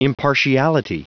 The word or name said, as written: impartiality